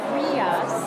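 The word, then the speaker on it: Rías: It's actually pronounced Rias, like real almost. Rias and then Vaixas, okay. But the spelling kind of throws you off. And V-A-I-X-A-S is how you spell the name Vaixas. So what it translates is to kind of river inlets, or Rias, and Vaixas is low.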